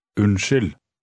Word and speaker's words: Unnskyld: Pay special attention to the beginning of the word. How do you say, "Excuse me"? – Unnskyld.